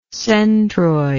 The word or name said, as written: centroidal